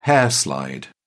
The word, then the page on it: hair slide: British English